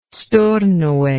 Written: Stornoway

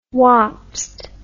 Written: wapsed

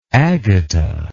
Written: agata

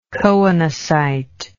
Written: choanocytal

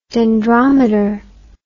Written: dendrometer

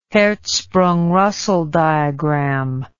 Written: Hertzsprung-Russell diagram

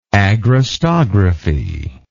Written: agrostographical

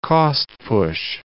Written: cost-push

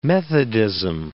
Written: methodism

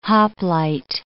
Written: hoplite

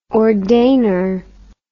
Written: ordainer

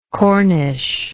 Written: Cornish

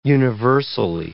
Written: universally

